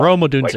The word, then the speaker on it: Rome Odunze: Rome Odunze.